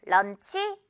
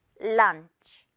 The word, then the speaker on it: lunch: 점심도 런치 아니죠. lunch. 런만 발음합니다, 여러분. 제대로. 그리고 실꼬리. lunch. lunch. 어렵지 않은데 한국말로는 아무래도 런치다 보니까 실수 많이 하는 것 같아요.